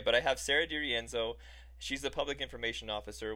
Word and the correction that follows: Dorenzo: Did I say your name right? – Um, pretty close. It's Dorenzo. – Dorenzo.